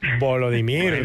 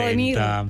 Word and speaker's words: Volodymyr: Última Volodymyr. – Pronunciación ha conseguido. Perfecto. El 40. – Volodymyr.